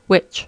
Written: which

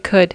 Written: could

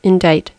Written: indict

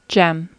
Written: gem

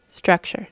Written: structure

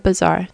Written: bazaar

